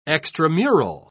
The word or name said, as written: extramural